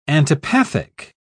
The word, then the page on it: antipathic: British English